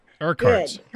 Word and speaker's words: Urquhart: U-R-Q-U-H-A-R-T. – Urquhart's. – And I'm, okay. – Urquhart's.